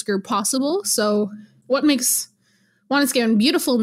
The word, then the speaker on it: Wanuskewin: So my master's thesis is focusing on plains archaeology, of course, specifically around the Apimahau Valley, which is the valley where Wanuskewin or Wanuskewin, if you say it properly in Cree, is situated.